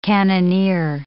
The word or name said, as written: cannoneer